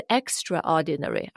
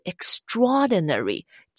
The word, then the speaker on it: extraordinary: 那这里呢有两个很好的形容词啊，一个是 ordinary，平常普通的，但如果你在前面加一个 extra，不是 extraordinary，而是 extraordinary，记住它的发音哦，它是一个词，就成了 Ordinary，完全的反义词，就是非常非常特别的，优秀的，难以忘怀的啊。